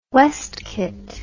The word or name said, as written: westcott